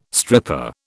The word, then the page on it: stripper: British English